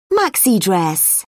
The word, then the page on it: maxi: British English